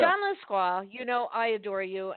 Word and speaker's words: Lescroart: it is pronounced Lesquois, but it is spelled Lescroart.